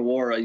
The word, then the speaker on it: Nwora: You mentioned Jordan Nwora.